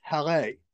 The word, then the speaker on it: Haleh: I want to make sure I said it correct because I know during the series that was brought up in the series that it had to be pronounced Halle. – Yes.